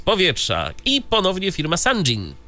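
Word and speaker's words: Sangean: Więc troszeczkę z pogodą, ale tak, firma Sanjin, może tak to mówić, Sanjin, tak się ją pisze się.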